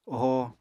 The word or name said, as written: ohoo